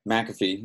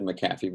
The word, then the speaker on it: McAfee: My name is Brett McAfee. You said McAfee, but it's fine. That's the proper pronunciation, but my family heritage has been bastardized over the years.